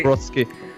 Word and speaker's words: Zabrocki: Zabrocki.